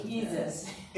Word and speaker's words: Jesus: If you read the word Jesus and it's I-E-S-U-S. – Jesus. – Now you would pronounce it Jesus.